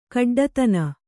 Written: ಖಡ್ಡತನ

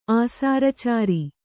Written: ಆಸಾರಚಾರಿ

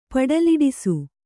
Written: ಪಡಲಿಡಿಸು

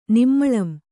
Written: ನಿಮ್ಮಳಂ

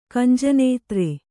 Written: ಕಂಜನೇತ್ರ

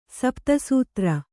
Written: ಸಪ್ತಸೂತ್ರ

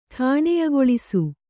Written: ಠಾಣೆಯಗೊಳಿಸು

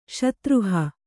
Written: ಶತ್ರುಹ

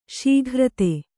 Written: ಷಾಗಿರ್ದು

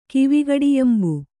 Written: ಕಿವಿಗಡಿಯಂಬು

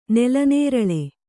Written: ನೆಲನೇರಳೆ

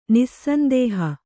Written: ನಿಸ್ಸಂದೇಹ

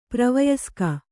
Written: ಪ್ರವಯಸ್ಕ

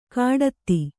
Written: ಘಟಿತ

